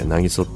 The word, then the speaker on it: 南木曽: まあ先日仕事でですね、なぎそっていうところに行ってきたんですけど、渚ってあの南木曽って書いてなぎそって読む